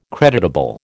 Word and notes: creditable: American English

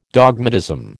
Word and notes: dogmatism: American English

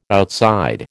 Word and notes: outside: American English

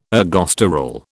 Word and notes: ergosterol: British English

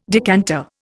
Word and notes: decanter: Indian

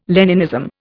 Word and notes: Leninism: Indian

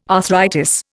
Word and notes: arthritis: Indian

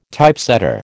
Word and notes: Typesetter: American English

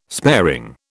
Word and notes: sparing: British English

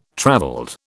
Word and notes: travelled: British English

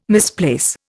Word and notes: misplace: Indian

